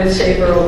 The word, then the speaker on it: SABR: In 1996, the Society, known by its acronym S-A-B-R, pronounced SABR,